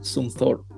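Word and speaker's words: Zumthor: Una de las arquitecturas más notables de Valls es el spa dermal diseñado por el premio Pritzker 2009 Peter Sumthor, Thor como el superhéroe de Marvel.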